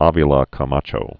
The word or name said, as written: Manuel Avila Camacho